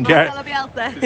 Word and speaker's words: Marcelo Bielsa: Do you think they'll survive, and do you think Marcel Bielsa, I can't pronounce his name. – Marcelo Bielsa.